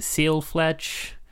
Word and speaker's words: Fledge: So some of the other games you've worked on, so I mean this, I'm probably going to pronounce this wrong, but is it Seal Fledge? – You got it, for sure.